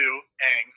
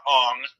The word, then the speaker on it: Aang: Because it was two main characters, and technically what they called Aang to Aang, not Ong, was even pronounced wrong.